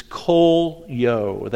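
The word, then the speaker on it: kolyo (κωλύω): It's kolyo. That's the Greek word that's used there, K-O-L-Y-O, long O's that are there.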